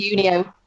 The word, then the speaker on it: Unio: And so the collective that you're now part of is the is it Unio or am I pronouncing that correctly? Is that right? it's Unio.